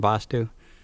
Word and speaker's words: Vastu: There are two Vastus that are one Vastu. Vastu pronounced with an A is pronounced quickly and spelled V with one A, S-T-U. And Vastu, pronounced with the longer time held for the A and spelled V-double-A-S-T-U.